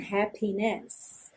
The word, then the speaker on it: happiness: And I wish you joy and happiness. Aqui também essa palavra happiness normalmente tem a sílaba tônica na primeira sílaba, ok?